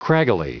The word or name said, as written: craggily